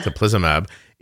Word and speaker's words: teplizumab: Teplizumab in prolonging insulin production in people who have recently been diagnosed with type 1.